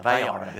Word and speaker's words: Vallarta: Is that how you say it? Puerto Vallarta? Vallarta. There you go.